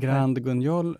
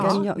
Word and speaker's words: Grand Guignol: Vet du hur man uttalar det? Grand Guignol. – Ja, Guignol. Guignol.